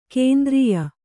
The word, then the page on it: ಕೇಂದ್ರೀಯ: kēndrīya